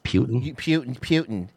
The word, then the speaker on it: Putin: I believe it's pronounced Putin.